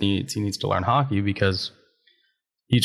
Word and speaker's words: haki: Is he learning haiki for the first time? I know Rayleigh hockey. Taught him a lot. – It's pronounced haki. Yes.